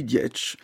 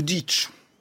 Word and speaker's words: Dietsch: et la musique à Louis Dietsch, ou Dietsch, pour prononcer à l'allemande.